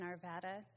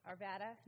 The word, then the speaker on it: Arvada: Who he was began 54 years ago in Arvada, Colorado, just outside Denver. Did I say that right? Arvada, not Arvada. So, Arvada, Colorado.